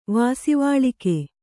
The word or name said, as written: ವಾಸಿವಾಳಿಕೆ